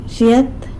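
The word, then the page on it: zhyet: San Lucas Quiaviní Zapotec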